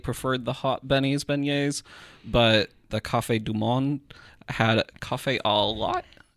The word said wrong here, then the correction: Café au lait: Is that how you pronounce it? – Café au lait.